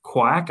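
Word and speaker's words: Kwak: You have people like law professor James Quack, I believe that's how you pronounce his name.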